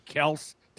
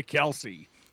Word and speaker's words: Kelce: they're especially Jason Kelce going after his old man for changing the pronunciation from Kels to Kelce. – Why did he? – Because he said everyone was calling him Kelce anyway.